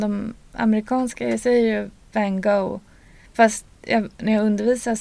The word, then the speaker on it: van Gogh: Ska vi uttala honom så som han uttalas på nederländska? – Ja, vi kan försöka. För det är väl jätteklurigt, eller? – Det är det, men vi kanske kan lära oss ja. Då uttalas han ju van Gogh. Van Gogh.